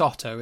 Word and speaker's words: AUTO: It's Otto, isn't it? – Is it Otto? – It's similar to Otto from The Simpsons, like the way Americans say auto, they say Otto.